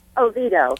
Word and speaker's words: Oviedo: Oviedo. – Oh, thank Oviedo.